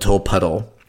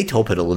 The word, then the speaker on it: Tolpuddle: It's pronounced, apparently, we're told, the way that it's spelled, Tolpuddle.